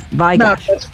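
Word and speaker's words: Vai/Gash: Vai Gash.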